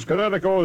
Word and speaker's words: Kinetico: Son, you should have remembered kinetic as in kinetic energy with an O on the end. Kinetico. – Kinetico. Spell it K-I-N-E-T-I-C-O.